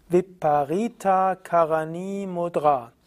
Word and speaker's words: Viparita Karani Mudra: Diese Übung oder dieses Mittel ist bekannt als Viparita Karani also als hm, ich will jetzt mal schauen, wie es richtig ausgesprochen wird. Es ist Viparita Karani Mudra.